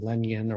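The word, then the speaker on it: Lenyn: but as I like to call him Lennon Sosa, but you're calling him Lenyon or whatever, so I guess that's the proper pronunciation.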